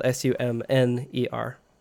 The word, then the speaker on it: Sumner: My name is spelled Sumner. It's it's like summer, like the season, but with an N in the middle of it.